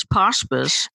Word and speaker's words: Pawspice: And I pronounce it, I love the pronunciation PawSpice, but it's really when I originated the term, it was PawSpice. – That's right. – To rhyme with hospice.